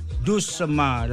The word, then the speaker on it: doucement: Is, how do you say it? Doucement? – Doucement, like a... – Doucement. – Doucement, slow, little by little.